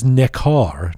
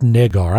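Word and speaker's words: Necar: By the way, it is Nekar, not Negar.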